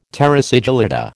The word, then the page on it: terra sigillata: American English